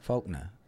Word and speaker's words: Faulkner: Faulkner. That's how Faulkner. – I say it. – Faulkner. – F-A-U-L-K-N-E-R. – I think the L is silent.